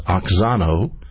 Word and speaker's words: auxano: In the Greek New Testament, it's pronounced oxano, A-U-X-A-N-O.